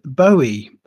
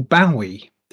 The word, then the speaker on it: Bowie: Let's talk David Bowie, or Bowie, depending on how you want to pronounce it. Yeah. I've always said Bowie, personally.